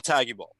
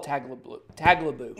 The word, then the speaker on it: Tagliabue: Paul Tagliabue. I'm saying his name wrong. – Paul Tagliabue.